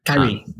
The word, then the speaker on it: Kyrie: And Kyle Irving, how do you pronounce that in the US? I can't do Australian. – Kyrie. – What's that, sorry? – Kyrie Irving. – Yeah, that's it.